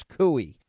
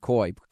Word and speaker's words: Perkins Coie: I think the proper pronunciation is Cooey. I'd said Coy. Cooey.